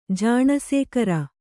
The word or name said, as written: ಜಾಣಸೇಕರ